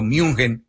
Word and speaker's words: München: Es decir, el que quiere dictar la última palabra va a ser el equipo Bayern Múnich o München,